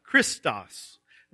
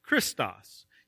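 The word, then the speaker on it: Christos (Χριστός): The first one has a long E sound, Christos. And it is a word that means good. It's the word that's found there for kindness. The second word is the word for Christ, Christos.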